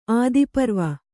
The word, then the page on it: ಆದಿಪರ್ವ: ādiparva